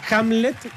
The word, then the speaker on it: Hamlet: Ahora, Hamlet, porque no lo dije mal.